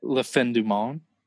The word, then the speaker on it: La Fin du Monde: I had recently turned 21 and I don't remember if it was Moosehead or I'm gonna say this wrong, but La Fin du Monde or something like that. – La Fin du Monde.